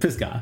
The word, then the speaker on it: Pisgah: Pisgah.